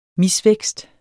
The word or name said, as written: mis-